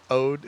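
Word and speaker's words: oud: Ode.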